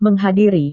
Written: menghadiri

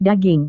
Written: daging

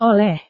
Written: oleh

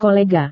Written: kolega

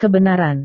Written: kebenaran

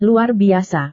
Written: luar biasa

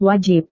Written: wajib

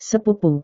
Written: sepupu

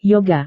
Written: yoga

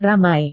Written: ramai

